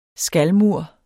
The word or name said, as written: skalmur